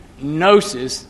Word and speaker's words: gnosis: Gnosticism is from the Greek word gnosis, the G is silent, and I'm going to turn to 1 Timothy 6 and verse 20.